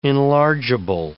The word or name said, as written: enlargeable